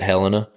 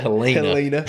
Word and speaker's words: Helena: Everyone else in the country pronounces it Helena. – Yeah. – But not in Alabama, baby. we pronounce it Helena. – Helena.